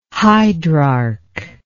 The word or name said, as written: hydrarch